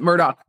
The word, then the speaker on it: Murdaugh: Look, it's spelled Murdaugh. It's hard to pronounce it Murdaugh.